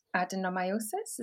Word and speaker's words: adenomyosis: And so we started doing the test early 2020, um, and they identified that I had adenomyosis. Is that how you say it? – Uh huh. Yeah.